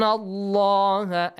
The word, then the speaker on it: اللَّهَ: إِنَّ اللَّهَ Again, the word, the letter lam in the word Allah is pronounced heavily. إِنَّ اللَّهَ Because there is a fatha sound before.